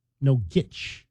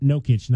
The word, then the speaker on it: Nogic: Nogic. Not Nokic, not like Joker, Jokic, Nokic, Nogic. – Not like Nokia. – Yeah, it's spelled N-O-G-I-C, Nogic.